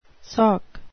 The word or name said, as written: sock